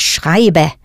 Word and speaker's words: schreibe: Richtig, ich schreibe. Pronounce that E ending for the ich person clearly.